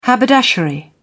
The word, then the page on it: haberdashery: British English